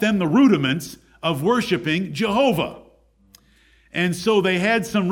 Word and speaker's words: Jehovah: which when pointed up with European vowels is pronounced as Jehovah.